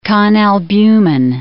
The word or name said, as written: conalbumin